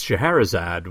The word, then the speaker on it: Scheherazade: But it's spelt funny, but it's Scheherazade,